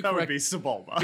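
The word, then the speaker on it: Sebulba: Saboba. – Oh, Sabulba. – Oh, that would be Sabulba.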